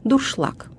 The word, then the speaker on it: дуршлаг: Например, «откинуть вермишель на дуршлаг». Ударение падает на последний слог «дуршлаг». Произносится и пишется как «дуршлаг».